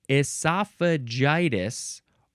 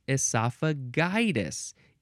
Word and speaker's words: esophagitis: It is esophagitis, or you can say it esophagitis. Either pronunciation for that G is fine.